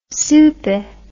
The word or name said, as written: souper